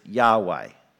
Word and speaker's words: Yahweh: So to the letters YHWH, we've traditionally added the vowels A and E, and so we then pronounce it Yahweh.